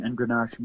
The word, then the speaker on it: engrenage: It's called engrenage, which is spelled E as in Edward, N as in Nancy, G as in George, R, E as in Edward, N as in Nancy, A-G-E, engrenage.